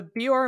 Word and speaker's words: Beor: But it is called the Beor Mountains, which is fine, except it's spelled B-E-O-R, Beor. But we also have J-E-O-D, which is pronounced Jode. I'm like, I feel like it should either be Geode and Beor, or Jode and Bor. – Yeah. – But having them both in the same chapter, having different, like, pronunciation.